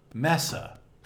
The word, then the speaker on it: Messa: Messa. – Messa. Is that how you say it? – Yeah.